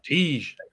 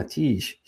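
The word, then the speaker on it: Tij: And you pronounced it well, very well there. – Tiige. – Yeah, Tiige.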